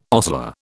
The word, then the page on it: ostler: British English